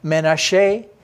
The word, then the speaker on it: Menashe: Now maybe you don't recognize it because I'm saying the Hebrew pronunciation. Ephraim, maybe you recognize it that way. Maybe you recognize Manasseh. In Hebrew, Menashe the Ephraim. That's how we say it.